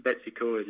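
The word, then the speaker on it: Betws-y-Coed: Betsy Coed? – Yeah. Betsy Coed, yeah.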